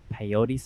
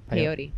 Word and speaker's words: peyote: So can you talk about more of this peyote ceremony? What, how, yeah. how do you pronounce it? Peyote.